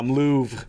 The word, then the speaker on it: Louvre: You know, there's a scene where they go to the Louvre. Am I saying that right? – Yeah, Luke? I think so. Yeah. That was good pronunciation there.